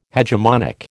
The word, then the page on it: hegemonic: American English